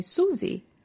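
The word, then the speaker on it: Suzi: And so when I was little, everyone used to call me Susie.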